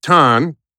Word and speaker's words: Tan: We got Teimojin Tan. Did I pronounce it your full name correctly? Tim Teimojin Just tan, like a suntan.